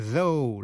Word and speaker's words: though: O-U-G-H còn phát âm là O trong chữ though là tuy nhiên, dẫu vậy. GH come. T-H-O-U-G-H.